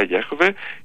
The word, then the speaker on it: Γιαχβέ: Στους 70 το όνομα του Θεού αποδίδεται ως Κύριος, γιατί όπως ξέρουμε το όνομα όπως προφέρεται στα εβραϊκά Γιαχβέ ήταν απαγορευμένο για τους Ισραηλίτες να το προφέρουν.